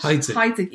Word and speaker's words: Heidsieck: Heidsieck.